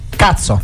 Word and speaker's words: Kutso: Cazzo! – Eccolo là, l'ha detto lui. Io volevo farvi complimenti per la scelta di questo nome, ricordiamolo, scritto K-U-T-S-O, però esatto, la pronuncia esatto. È all'inglese.